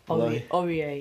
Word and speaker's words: Aurier: or Aurier, if that's how you say it, at Tottenham. – Aurier. – That's the one.